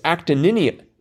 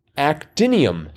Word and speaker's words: actinium: I believe it's actium. – Actium. – Mm-hmm. – Oh. – I'm going to check. Oh, I'm sorry. It's actinium. Wait, now I can't even pronounce this. Actinium. Actinium. Not actium. Just actinium.